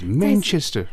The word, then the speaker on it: Manchester: 但是我在这边跟大家说，如果是在英国的话呢，但你想要发音的更像英国人，Manchester 才是对的。你重音要放在后面。 – Oh my my apologies, Manchester. – Manchester。